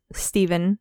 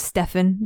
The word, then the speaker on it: Stephen: So he is in a band called the Sex Bobombs with his friends, Stephen, also sometimes pronounced Stefan, depending on how you feel about names and English as a language, and then Kim.